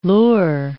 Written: lure

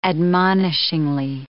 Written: admonishingly

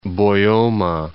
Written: Boyoma Falls

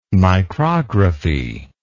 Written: micrography

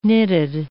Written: nitid

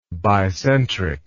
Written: bicentric

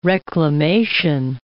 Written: reclamation

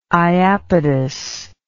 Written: iapetus

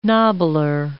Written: nobbler

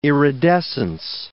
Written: iridescence

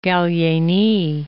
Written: Gallieni

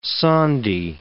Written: sandhi

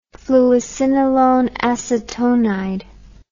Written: fluocinolone acetonide

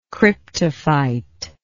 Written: cryptophytic

